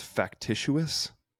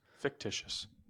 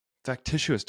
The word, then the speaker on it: factitious: But the one that I saw the other day was, and I don't know if I'm pronouncing this right. Let me double check real quick. I think it's factitious disorder. – I think fictitious. It's a word.